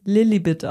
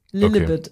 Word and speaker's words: Lilibet: Und die haben gemeint, man spricht es Lilibet aus. Lilibet. Okay. Also das E, es steht zwar ein E, aber es wird so ein bisschen I-mäßig ausgesprochen.